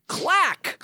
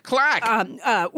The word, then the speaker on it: claque: It's the word clack. – Clack. – Which is spelled C-L-A-Q-U-E.